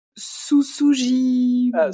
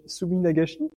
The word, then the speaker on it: Suminagashi: le Susujiban. – Suminagashi.